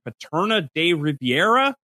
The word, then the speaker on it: Paterna de Rivera: The three routes test here in Paterna de Rivera. Did I say that right? Jess, you're the smart team. – It, it sounds right to me.